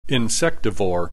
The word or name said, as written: Insectivore